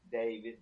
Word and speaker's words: Davis: A-L-Al Davis, D-A-V-I-S.